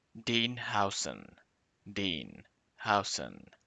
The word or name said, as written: Dean Huijsen